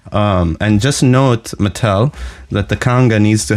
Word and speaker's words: Mattel: So you should say it like an American, correct? – Yes. – So Mattel.